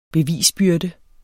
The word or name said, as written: bevisbyrde